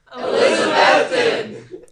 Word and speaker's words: Elizabethton: The school sits in the shadow of the Smoky Mountains, and I needed the students' help pronouncing its name. – Elizabethton!